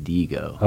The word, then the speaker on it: Diigo: D-I-I-G-O? – D-I-I-G-O. And somebody may correct me, but I'm pretty sure that's the pronunciation, Digo.